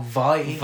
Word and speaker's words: Viy: V-I-Y, is that how you say it, or Vi? – Vi.